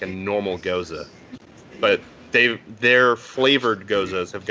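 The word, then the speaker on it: Gose: So for people who don't know what a Goza is, it's pronounced Goza.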